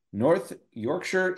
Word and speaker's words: North Yorkshire: From North Yorkshire, England. I said it right that time.